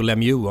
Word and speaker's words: Lemieux: Jag tänker ju Pittsburgh, men så jag hade faktiskt inte koll på att det var Montreal som var hemstaden, men Lemieux, ja, hur uttalar man det på franska? – Han sa ju faktiskt själv Mario Lemieux om sig själv, så det ska vi, ja.